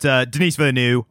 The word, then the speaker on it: Denis Villeneuve: But Denis Villeneuve, I pronounce it correctly because it's one of the few foreign names that I can pronounce correctly because I heard it enough times.